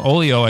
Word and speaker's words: Oglio: Oglio Records. I know I'm pronouncing it wrong. Oleo, I think, is how you pronounce it. But whatever. It's a silent G.